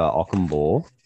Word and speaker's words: Ockhambor: Looks like he's got the spot over Ockhambor. That's how you say his name.